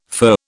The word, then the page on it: fur: British English